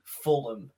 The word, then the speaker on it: Fulham: At least they'll pronounce them all right, though, over there, because Tony Khan will tell them. It's Fulham.